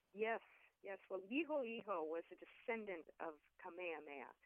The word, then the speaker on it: Liholiho: Tell us about this particular leader of Hawaii, Liholiho, is that how you pronounce that name? – Yes, that's how you pronounce it.